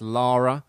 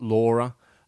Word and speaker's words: Lara: a more of a push for female characters in gaming with, yeah, I mean, we had Lara Croft, and that's how you say it, people. It's Lara, not Laura. I hear a lot of people say Laura, it's Lara.